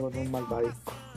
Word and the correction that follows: malvavisco: ¿Cómo se dice? ¿Malvadisco o malvadisco? – Malvavisco.